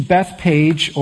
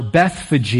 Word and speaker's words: Bethphage: It says, as they approached Jerusalem, they came to Bethpage or Bethphage. You can pronounce it either way, potato, patata, doesn't really matter. I'm going to say Bethpage for just the sake, it's easier to pronounce.